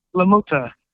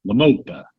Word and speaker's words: La Mota: Is it La Mota, La Mata? – La Mota. – La Mota, okay.